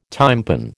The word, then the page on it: tympan: American English